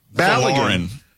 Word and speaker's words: Balogun: Balogun. Is that how you say it? – Balogun.